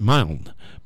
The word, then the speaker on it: Milne: Milne, M-I-L-N-E. Or you may pronounce it Milne, but I pronounce it Milne, okay?